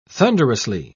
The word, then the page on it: thunderously: British English